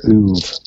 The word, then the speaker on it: oeuvre: it's a wholly unique corner of their oeuvre. Is that how you pronounce that word? – Yes, it is.